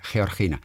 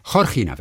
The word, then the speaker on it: Georgina: Deswegen wird sie Georgina. Nee, Georgina wird sie ausgesprochen. Nicht okay.